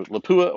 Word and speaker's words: Lapua: You know, Lapua or Ely.